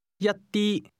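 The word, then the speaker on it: Yatti (一啲): Yatti. Ti. Yat. Yatti. Yatti. – Say some or a little. – Yatti. – This word is pronounced with the same high level tone heard in sik tang.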